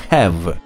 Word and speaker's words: have: «Вы произносите его», – выговаривала ему дама, – «как если бы оно писалось «э-в». А это не так. На конце пишется «е». «Но я думал», — сказал в свое оправдание ученик, «что «е» в слове «have» не читается».